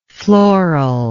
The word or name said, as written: florally